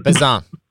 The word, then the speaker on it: Bazin: I will not even pretend that I know how to pronounce that. – Bazin. – Andre Bazin.